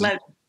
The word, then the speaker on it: Levy: Is Levy.